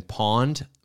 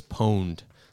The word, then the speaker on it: Pwned: And you would think that would be Have I Been Pawned? But it's Pwned, like owned.